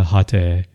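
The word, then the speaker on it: Hatae: Also, I love this photo of Hana Hate, who played, and again, that's the Japanese pronunciation. Maybe Hate is more common there,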